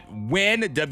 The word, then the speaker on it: wynn: We got win, W-Y-N-N, another W sound. Yog, Y-O-G-H. That was a yog. – A ch sound. Ash, A-S-H.